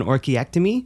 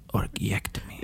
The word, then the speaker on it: orchiectomy: I had an orchiectomy, oh, so oh, I, I, I, said it right. I said I read it in my head, and I was like, "That's an orchiectomy."